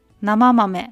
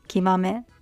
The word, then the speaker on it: 生豆: 生豆って読むと思ったんですけど、木豆と読みます。で、これは、 Amazon で、 1 キロ 1500 円ぐらいでした。ちょっと高くてもあまり質が悪くないもの、評判がいいものを買いました。で、この木豆は最初信じられないぐらい豆の匂いがします。